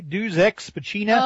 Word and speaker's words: Deus Ex Machina: Hey, speaking of pronunciations, what is that D-E-U-S-E-X-M-A-C-H-I-N-A, Deus Ex Machina? – Oh, Deus Ex Machina, yeah.